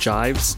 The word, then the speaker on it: Jives: Oh, was Dopamine Thumb by, I believe it's pronounced Jives, J-I-V-E-S, and that was just dropped on More Creativity Records.